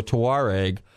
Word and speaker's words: Touareg: T-O-U-A-R-E-G.